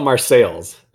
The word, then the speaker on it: Marseilles: They, they call it Marseilles. It's spelled like Marseille, but it's Marseilles, Illinois.